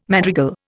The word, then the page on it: madrigal: Indian